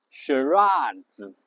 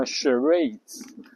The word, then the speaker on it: charades: 然后英式发音呢，读 charades。这个发 r 的音，然后美式呢发 charades，然后重音都在第二个音上面。